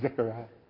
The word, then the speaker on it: Zechariah: Is that Zechariah? – That's fine. – Is that fine? – it's spelled with a Z-E, but... – Zechariah.